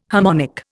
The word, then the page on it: harmonic: Indian